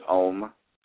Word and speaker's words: Om: It's Om, O-M, Om.